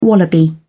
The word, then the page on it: wallaby: British English